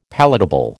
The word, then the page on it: palatable: American English